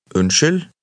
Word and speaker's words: unnskyld: Unnskyld.